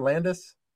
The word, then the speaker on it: Landes: This week I am joined by a very special guest in Matt, Matt Landes. Matt, I didn't even ask. Is that the right way to say your name, Landes? – Yeah, you nailed it.